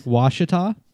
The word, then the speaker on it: Ouachita: Ouachita.